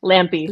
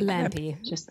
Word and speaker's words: Lampe: Today, we have a beautiful guest, Luci Lampe. Am I pronouncing that correctly? – Very close. It's Lampe. – Lampe.